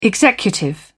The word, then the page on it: executive: British English